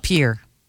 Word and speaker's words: Pierre: Pierre. – That's right. And you even pronounced it correctly.